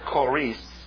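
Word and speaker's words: choris (χωρίς): The word apart from, Greek word choris, accent on the last syllable, long O.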